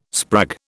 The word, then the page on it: sprag: British English